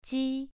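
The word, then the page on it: ji: tone 2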